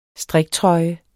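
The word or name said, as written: Striktrøjer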